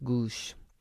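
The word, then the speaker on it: Oghuz: I think you pronounce it Goosh.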